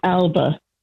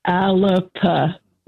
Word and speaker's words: Alba: I didn't actually hear it, but I just wanted to say to you that it's not called Alba. It's Alapa. That's how it's pronounced.